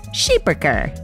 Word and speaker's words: Schipperke: This week's breed of the week is a Schipperke, or if you're from Belgium, correctly pronounced as Schipperker.